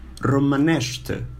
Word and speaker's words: Romanește: Okay, and the word for the language Romanian is Romanește. Yes, you can repeat it. You can use like starting from the end. Ște, nește, mănește, Romanește. Romanește.